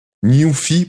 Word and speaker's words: NuPhy: j'ai trouvé une compagnie qui s'appelle Nufi Newfie, pour, le, pour le prononcer en anglais, c'est ça très drôle, très mémorable. N-U-P-H-Y.